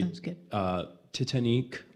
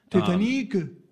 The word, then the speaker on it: Titanique: I say Titanique. Some people say Titanique. I don't know. I just think it's meant to be however Celine Dion would say the word Titanic.